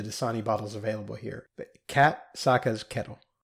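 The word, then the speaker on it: Kat Saka's Kettle: Bro, it's Kataska's Kettle. It's not that hard.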